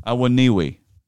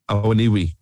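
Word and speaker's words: Awoniyi: Awoniwi. – Awoniwi will get goals.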